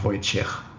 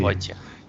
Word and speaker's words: Wojciech: Okay, also es wird nicht mit quasi K am Ende ausgesprochen, sondern mit einem CH. – Ja, CH, Wojciech. Okay. Wojciech.